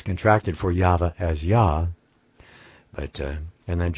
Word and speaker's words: Yahweh: and so we pronounce YHVH as YAHVAH, Yahweh, or Yahweh. That's how we pronounce that.